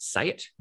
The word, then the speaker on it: Seat: So you may have remembered from a few years ago, there was a brand called Say It in Australia. It was written Seat. That's not how you say it.